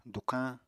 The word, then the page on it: dukañ: apatani